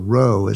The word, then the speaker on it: Roth: And so Dieter Roth is how it's pronounced, but it's spelled R-O-T-H.